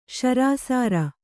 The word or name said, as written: ಶರಾಸಾರ